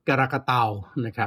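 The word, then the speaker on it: Krakatau: กรากาเตานะ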